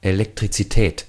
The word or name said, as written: electricity